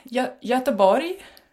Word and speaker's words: Göteborg: Do you say Göteborg? How do you say it? – Almost. Göteborg. – Oh, not at all. Göteborg. – Yeah, that's the Swedish way of saying it.